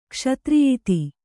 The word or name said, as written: ಕ್ಷತ್ರಿಯಿತಿ